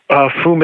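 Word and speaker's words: Fumée: Oh, Fume is F U M E E. And the last E has one of those tilty things on it because it's French.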